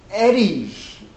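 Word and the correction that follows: Edy's: Edie's, it's pronounced.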